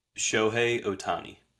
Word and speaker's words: Shohei Ohtani: Shohei Ohtani.